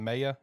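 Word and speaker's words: Maye: Maya? Is that how you pronounce it? – Yeah.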